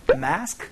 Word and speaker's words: mask: Have you seen my mask? 라고 얘기를 해주시면 됩니다. 영어로는 발음이 마스크가 아니고 마스크라고 매 소리가 나니까 요거 조금 주의해 주시면서 연습 한번 해보도록 하죠.